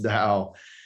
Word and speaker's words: Tao: So this Eastern Chinese philosophical concept, and you nicely point out, it looks like Tao, but it's pronounced Tao.